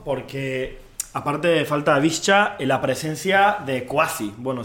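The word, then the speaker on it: Višća: Marek Hamšík, la leyenda del Nápoles, clave en el título de liga de la temporada pasada, y también Edin Visca. No, o sea, se lee como Visca al Barça, pero se pronuncia con una CH.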